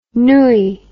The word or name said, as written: nouilles